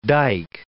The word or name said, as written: dyke